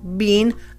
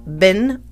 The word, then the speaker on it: been: It's B-E-E-N. It looks like bean, long E, and the Brits pronounce it like that, bean. I have been to the store and back. I have been to the store. But in the United States, we pronounce it bin or ben.